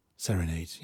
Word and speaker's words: Serenade: Serenade, ja, genau so wird das ausgesprochen. Das ist der Eröffnungstrack des Albums von Dean Hilsen und The Scar Vendors.